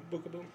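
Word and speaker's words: Beukeboom: Out of the oldies, the first two defensemen I see are Bruce Bell and Jeff Beckboom. I think that's how you say his name. – Bookaboom, yeah.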